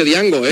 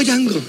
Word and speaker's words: Django: Con lo tío que parece Django, ¿eh? – Yo le llamo Django. – Django. – Porque la D delante no la vaya a pronunciar.